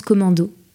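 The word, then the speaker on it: CMD+O: Quatre lettres et le signe plus, CMD plus O. Ça sonne comme un raccourci clavier, mais ça se prononce commando.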